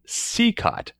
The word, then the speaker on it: Cicotte: Well, I thought it was Cicotte, yeah. But then I looked this one up, and at least according to Baseball Reference, it says pronunciation Cicotte. So, oh, yes, that's right. Yeah, I think that is right.